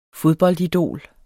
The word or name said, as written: fodboldidol